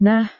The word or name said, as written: nah